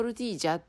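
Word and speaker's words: tortilla: まあ、いわゆる、いわゆる、えー、トルティーヤ、えーえーとスペイン語で言うとトルティージャって発音する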